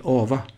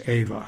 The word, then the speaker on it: Ava: Jag minns annars när vi stod framför filmaffischerna på matinéföreställningarna i början på 50-talet så heter hon alltid Ava Gardner, men det ska uttalas Ava har jag förstått.